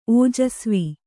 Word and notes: ಓಜಸ್ವಿ: ōjasvi

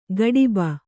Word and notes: ಗಡಿಬ: gaḍiba

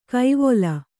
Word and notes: ಕೈವೊಲ: Kannada

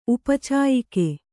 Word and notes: ಉಪಚಾಯಿಕೆ: kannada

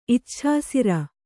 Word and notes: ಇಚ್ಛಾಸಿರ: icchāsira

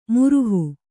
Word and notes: ಮುರುಹು: kannada

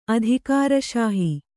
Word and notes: ಅಧಿಕಾರಶಾಹಿ: adhikāraśahi

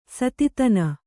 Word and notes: ಸೂತತನ: sūtatana

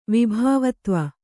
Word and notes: ವಿಭಾವತ್ವ: kannada

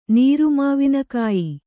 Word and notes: ನೀರುಮಾವಿನಕಾಯಿ: Kannada

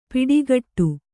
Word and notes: ಪಿಡಿಗಟ್ಟು: piḍigaṭṭu

